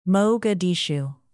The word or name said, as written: mogadishu